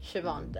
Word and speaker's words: Siobhan: I've, male 32, been dating Siobhan. Siobhan. That's how you say it, apparently. – I was gonna say Siobhan. – Yeah, but I've met someone with that name and they say Siobhan.